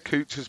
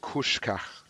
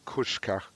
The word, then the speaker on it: Kucka: I got my phone out, I went onto Kucha's picture, and straight away they said, and I said, Juraj Kucha. They was like, no, it's Juraj Kuška. So that is the official pronunciation, Juraj Kuška.